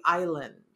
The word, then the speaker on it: island: Now, here's the thing about when you say island, because it's pronounced just like the piece of land out in the ocean, island, but it does not have the S. There is no S in island.